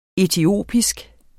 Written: etiopisk

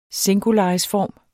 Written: singularisform

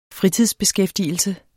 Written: fritidsbeskæftigelse

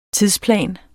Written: TIDSPLAN

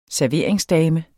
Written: SERVERINGSDAMERNE

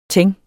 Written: Teinten

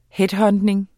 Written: headhunting

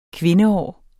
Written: kvindeår

